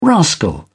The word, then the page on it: rascal: British English